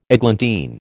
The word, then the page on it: eglantine: American English